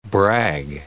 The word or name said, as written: brag